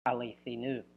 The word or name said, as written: alithinou